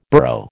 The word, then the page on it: borough: American English